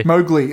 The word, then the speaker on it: Mowgli: Mowgli, that's right.